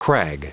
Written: crag

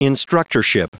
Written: instructorship

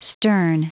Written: stern

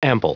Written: ample

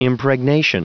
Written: impregnation

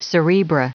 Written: cerebra

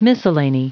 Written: miscellany

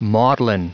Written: maudlin

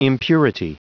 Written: impurity

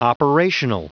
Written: operational

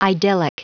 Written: idyllic

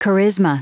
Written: charisma

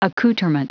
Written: accouterment

